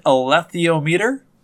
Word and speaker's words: alethiometer: So it's pronounced an alethiometer. It is not actually a compass. – An alethiometer or an alethiometer? – Probably an alethiometer.